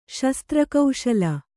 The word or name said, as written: ಶಸ್ತ್ರಕೌಶಲ